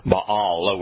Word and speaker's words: Baal: Baal is the Baal, would be the proper Hebrew pronunciation.